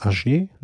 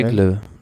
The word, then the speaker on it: Aigle: Naja, und dann kommt die Etappe am Sonntag von Wie spricht man das? Agé? Nach Châtel? – Aigle.